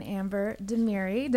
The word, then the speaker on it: Demiri: Rhea and Amber Demiri. Did I say it right? Thanks for having us. – Yes. – You said it perfect.